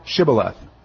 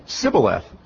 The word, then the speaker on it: Shibboleth: Then they said unto him, say now Shibboleth. And he say, Sibboleth, for he could not frame to pronounce it right.